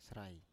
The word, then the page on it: srai: Biak